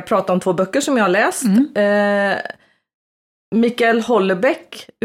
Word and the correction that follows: Michel Houellebecq: uttalar man hans namn så? – Du, det är Michel Houellebecq har jag hört.